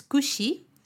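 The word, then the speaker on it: kushi (櫛): Mira, peine en japonés es kushi, esa es la pronunciación.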